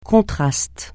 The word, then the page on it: contraste: francais